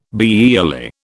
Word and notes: biyearly: British English

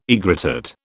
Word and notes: aegrotat: British English

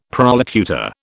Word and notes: prolocutor: British English

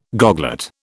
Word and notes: goglet: British English

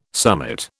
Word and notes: summit: British English

